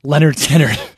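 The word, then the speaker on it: Lynyrd Skynyrd: Debut album is actually titled Leonard Skinner, pronounced Leonard Skinner. – Right. – But the crazy thing about it is it's spelled L-Y-N-Y-R-D, S-K-Y-N-Y-R-D. Y is the only vowel in both words, so why would they be pronounced differently? – I think they're stylized.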